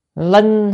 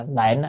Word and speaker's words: linh: Linh hoạt ngũ đức, chữ này đọc là linh à, có một số bản người ta dịch không biết, người ta dịch là lệnh á, cái chữ này linh mới đúng, linh là khiến cho, hoạch là thu hoạch à, tức là đạt được gì, ngũ đức.